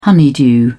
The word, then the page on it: honeydew: British English